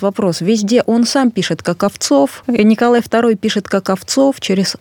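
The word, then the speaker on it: Коковцов: Почему именно Владимир Николаевич Каковцев? – Если позволите, я сразу поправлю фамилию со спорным ударением, потому что... – Каковцов? – Да. Его предки, его, ну, скажем так, деды, прадеды, возможно, были Каковцевы.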